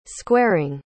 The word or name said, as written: squaring